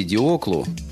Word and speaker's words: Диокл: Еще в юности Диоклу, так произносилось его имя на греческий манер,